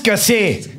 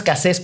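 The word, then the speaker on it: Cassez: Florence Cassé. No, pero es Florence Cassés porque esa Z sí se pronuncia, no sé por qué diablos.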